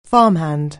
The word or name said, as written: farmhand